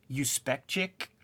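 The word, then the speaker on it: Juspeczyk: So like the last name is, when you hear it, you know, in those motion comics, it's Uspechik, but it's spelled like Jew, and the character is one kind of like the old comic creators who kind of hid their ethnicity.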